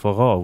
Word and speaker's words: forró: a great Just when Rhiannon played this Briggs tune for me, it reminded me very much of forró, which is a music from the northeast of Brazil, which is very accordion-driven. – Right, right. – So similar rhythms, you know. – Spelled F-O-R-R-O, but exactly. Pronounced forró.